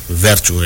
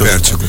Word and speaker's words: Vértigo: Vértigo, esse Vértigo. É, o, é a pronúncia correta, né?